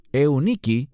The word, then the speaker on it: Eunice: Y tu madre, Eunice, que literalmente en griego es Euniki y significa la buena victoria.